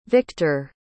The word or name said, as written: Viktor